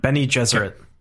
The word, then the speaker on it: Bene Gesserit: Bene Gesserit? – Yeah, that's right. – Bene Gesserit.